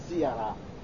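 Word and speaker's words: سيراء: وقوله سيارة، الحلة قال حلة سيارة، هذا وصف لهذه الحلة، سيارة هكذا نطقها بكسر السين مهملة بعدها مثنى تحتية ثم راء مهملة ثم ألف ممدودة، وك كإيش عنباء، يعني سيارة يعني عنباء، هذا وزنها.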